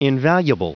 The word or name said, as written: invaluable